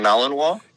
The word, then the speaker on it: Malinois: The Belgian Malinois.